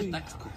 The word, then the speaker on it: Taxco: Se dice Taxco.